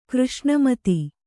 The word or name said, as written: ಕೃಷ್ಣಮತಿ